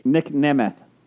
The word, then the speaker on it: Nemeth: I'm not saying I'll do that only if you learn how to pronounce his name correctly. – Nick Nemeth. – There we go.